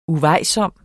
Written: UVEJSOMT

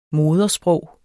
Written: modersprog

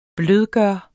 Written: blødgøre

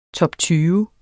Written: TOPTYVE

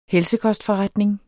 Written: helsekostforretning

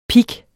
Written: pik

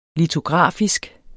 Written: litografisk

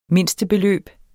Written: mindstebeløb

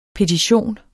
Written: PETITIONENS

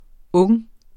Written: ung

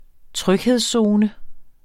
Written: tryghedszone

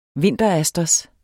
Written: vinterasters

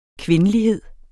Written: kvindelighed

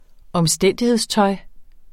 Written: omstændighedstøj

